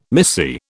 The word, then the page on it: missy: British English